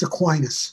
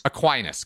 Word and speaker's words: Aquinas: It's usually pronounced Aquinas. – Aquinas, good.